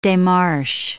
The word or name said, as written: demarche